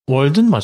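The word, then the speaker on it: Walden: 그래서 원래 이제 다마로랑 경쟁 구도에 있던 그 스트리밍이랑 엔터테인먼트 부문을 맡고 있던 워든인가? 뭐라고 읽어야 될까요? – 잠시만요. – 월든? – 월든 맞을 거예요. 네, 월든.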